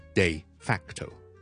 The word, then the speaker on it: de facto: 我哋今日咧先讲呢一个，我哋成日睇新闻咧，尤其是国际新闻咧，可能会见到呢、這、一个就系、是、咧 de 跟住隔一隔 facto de facto 读音系 de day 咁嘅 de 跟住 facto facto，就意思上咧就系、是、一个实际上嘅。